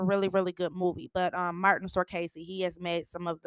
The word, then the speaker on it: Martin Scorsese: Martin Scorsese.